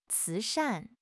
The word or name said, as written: 慈善